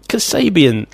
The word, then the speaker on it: Kasabian: This one is for fire, and it's called Fire by Kasabian? – You don't know how to say it! – No, I don't. Say it. – Kasabian.